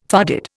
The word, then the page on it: farded: Indian